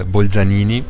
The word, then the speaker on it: Bolzanini: Alors, en italien, on dit Bolzanini. En allemand, on dit Bozner.